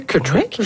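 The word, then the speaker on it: Kutryk: Celui qui a peut-être moins bien dormi, c'est Monsieur Kutrick. J'ai bien prononcé ça, je pense, Monsieur Kutrick. – Oui, je vois son nom justement à l'écran et ça me semble correspondre.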